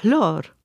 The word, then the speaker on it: hlor: Bien entendu, par exemple, pour le mot latin « flore », qui est la fleur, eh bien, en gascon, on a « hlor ». Vous avez entendu le H, hein ? – On le prononce.